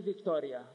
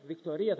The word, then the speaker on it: Βικτωρία: Έχει μια μικρή λιμνούλα στην επάνω αριστερή μεριά του χάρτη που τη λένε Βικτωρία Γιάννη ή Βικτώρια. Βικτωρία θα πρέπει να είναι. Όταν είναι η Λίγουσα είναι μακρά, η Προπαραλίγουσα δεν τονίζεται.